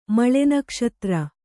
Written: ಮಳೆನಕ್ಷತ್ರ

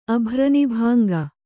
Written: ಅಭ್ರನಿಭಾಂಗ

